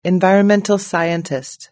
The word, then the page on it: environmental scientist: British English